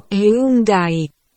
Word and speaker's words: Hyundai: A čo hovorí na výslovnosť Hyundai? Teta Google? Hyundai. Všetko je OK.